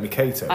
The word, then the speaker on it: Mercator: MacArthur. – Good. – Could you pronounce it right, though, please? – Is that how you say it? Mercator? – I don't know. I always call them Mercator maps.